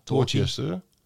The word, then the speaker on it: Dorchester: En dan heb je daar in die contraien heb je ook nog, behalve Weymouth, heb je nog, even kijken, Dorchester volgens mij. Zeg ik het goed? – Ja. Dorchester.